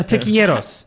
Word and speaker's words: tequileros: No, tequileros. – It's so the L is hard. It's a hard L.